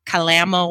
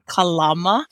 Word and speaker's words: Kalama: There's even a city there in Washington state, which everybody pronounces incorrectly by calling it Kalama, Washington, but it's actually Kalama, Washington.